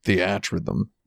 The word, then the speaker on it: Theatrhythm: I believe it's pronounced Theatrhythm. – Theatrhythm.